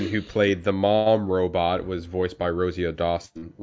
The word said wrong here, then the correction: Rosario Dawson: Rosario Dawson.